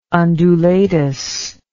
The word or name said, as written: undulatus